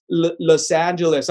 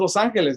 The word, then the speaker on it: Los Angeles: They're like, así no se pronuncia, especially when people say, it's Los Angeles. I'm like, no, it's Los Angeles.